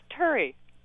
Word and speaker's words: Turi: This is Turi from MIT. – Terry. Terry. Hi, Terry. – It's Turi. – Oh, Turi. What, how do you spell that? – T-U-R-I. – T-U-R-I.